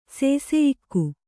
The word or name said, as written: ಸೇಸೆಯಿಕ್ಕು